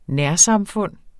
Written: nærsamfund

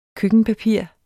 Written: køkkenpapir

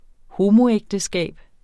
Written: homoægteskab

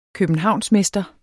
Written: københavnsmester

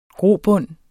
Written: grobund

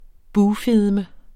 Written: bugfedme

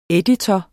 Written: EDITOREN

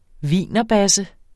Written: wienerbasse

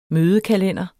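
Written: mødekalender

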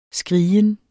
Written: skrigen

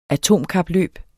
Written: atomkapløb

